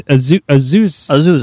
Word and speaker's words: Azusa: It's Azusa. You got it. It's not hard. Azusa.